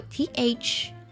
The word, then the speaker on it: thorough: 还有彻底的这个单词啊，拼作 THOROUGH，英式的发音呢读作 sa-jara-sara，后面的 OUGH 直接呢是一个简简单单的的音，而呢在美式的发音当中呢会读作 thorough，对比一下 Thorough。